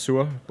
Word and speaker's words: sur: And I like the way you guys pronounce Sir, because it's in Danish it's pronounced Sur, but you pronounce it Sir, so yeah.